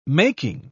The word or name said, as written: making